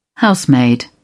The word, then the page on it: housemaid: British English